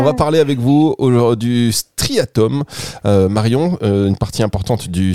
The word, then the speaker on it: striatum: Striatum, S-T-R-I-A-T-U-M.